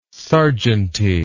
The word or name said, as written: serjeanty